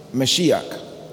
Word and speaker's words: Mashiach: All right, it's the word Mashiach. You know, Mashiach is Hebrew and the Greek is Messiah.